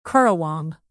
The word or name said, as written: currawong